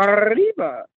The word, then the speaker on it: Paribas: Paribas. – Yeah, no, I don't think they roll the R. That would be from España.